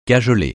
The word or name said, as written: cajoler